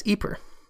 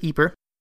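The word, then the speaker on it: Ieper: So I have been pronouncing the name as Ypres, which is the French name for the city. But the city is actually located in the Flemish region of Belgium, and I am told they pronounce it as Ypres, and it is spelled quite differently. Apparently, this is a rather sensitive subject in the region. So from here on out, I'm going to pronounce it Ypres, uh, so don't get confused. Ypres equals Ypres.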